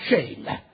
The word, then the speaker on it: Shem: I looked up that brother Allen and I was looking in scripture, and everywhere you look, that word shame is spelled S-H-E-M, but it's pronounced shame.